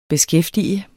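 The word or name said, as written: beskæftige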